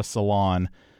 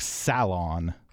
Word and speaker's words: salon: Okay, the first word, we call it a salon. I don't know exactly what British people would call this, but I'm guessing it's something more like salon. – Yes, apparently. – So continuing, I guess, with the syllable stress thing, B-A-L-L-E-T is ballet here in the United States.